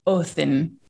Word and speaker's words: Odin: And for all of you guys that are listening out there, we know that saying Odin is incorrect. – Correct. – But right. Yeah, because if you look up actual Scandinavian pronunciation, it varies, but in general, it's the D is a TH sound. So mm-hmm. his name's actually supposed to be pronounced like Othin.